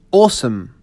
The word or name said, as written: awesome